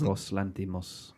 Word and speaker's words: Yorgos Lanthimos: My highlight of the year last year was Yorgos Lanthimos.